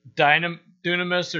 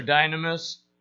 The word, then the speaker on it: dunamis: The first one he uses is dunamis or dynamis, depends on how you pronounce it.